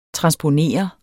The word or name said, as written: TRANSPONER